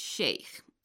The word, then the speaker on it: Sheikh: In this instance, Sheikh is not an English word, and so we pronounce it like its original form, that is Sheikh.